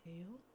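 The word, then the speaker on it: Taeil: Is that how you say his name? Tail? – Mm-hmm.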